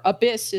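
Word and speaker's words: ABIS: you play as Kaname Date, who is a cop in near future Tokyo working with Abyss, Abyss is the way that they pronounce it, the Advanced Brain Investigation Squad.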